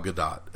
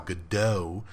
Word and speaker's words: Gadot: I always thought it was Gal Gadot. The T was silent. I started to get a feeling that it was Gadot when she was doing press for the Justice League movie, and she didn't correct anybody that said Gadot.